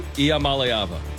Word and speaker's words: Iamaleava: Nico Iamaleava, which was pretty good. – Iamaleava. – Nico Iamaleava.